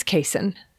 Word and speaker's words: caisson: And the real pronunciation, as several listeners pointed out, thank you, is caisson.